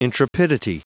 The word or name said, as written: intrepidity